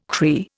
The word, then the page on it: Cree: Indian